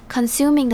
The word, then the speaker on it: consuming: 接着我们来看 consuming 这个字， consuming 的重音在第二个音节，也就是 suming 这个音节。那要注意它发音的地方是 O 是发成 E 的声音 consuming，接着 U 是发成 U 的发音。